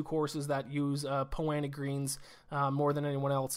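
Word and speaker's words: Poa annua: I mean, it always throws me off, because it's like 2 words. It's P-O-A space A-N-N-U-A. So I want to always say Poa Anua, but it's just kind of pronounced Poana.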